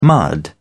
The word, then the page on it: mud: British English